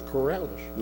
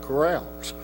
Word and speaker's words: Corrales: It was Corrales, not Corrales.